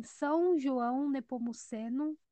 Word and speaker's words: São João Nepomuceno: É, foi gravado por Aldo Torres no estúdio Versão Acústica em São João Nepomuceno.